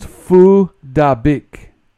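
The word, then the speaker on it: Phuc Dat Bich: It's actually pronounced Phu Da Bích, but if you read it, you know, phonetically that way.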